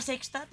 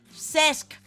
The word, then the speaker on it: Cesc: Però senyors de la Sexta, Telecinco i Televisió Espanyola, que no és tan difícil, home, que es pronuncia Cesc, com si fos AMS, joder!